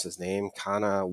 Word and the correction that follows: Connaughton: whatever. – Connaughton. You know,